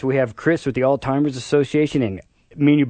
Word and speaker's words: Alzheimer's: I like to say, remember all's fair. Alzheimer's,